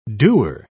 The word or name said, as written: doer